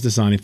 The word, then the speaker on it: Dasani: Four is Dasani for sure. – Mm-hmm. Pronounced with a Z, which I've never heard before. – Dasani. – It's like Boise.